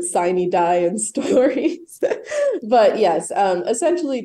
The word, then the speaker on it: sine die: Yes, it is sine die, and it is the end of the current legislative year.